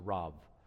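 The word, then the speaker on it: Rab: So you'd pronounce that B, R-A-B. You'd pronounce it more like a V, so Rav, something like that.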